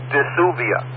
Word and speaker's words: Dsuvia: The brand name is spelled D-S-U-V-I-A, so the best I could guess is Dysuvia.